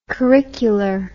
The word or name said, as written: curricular